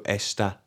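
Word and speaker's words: está: Esta rhymes with vesta. You know, those curries in a box.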